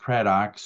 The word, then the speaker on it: Craddock: They can connect by finding me on LinkedIn, you know, Joel Craddock. And the last name is pronounced Craddock, spelled like Haddock, or only with a C on the front and an R, but yes, like the fish.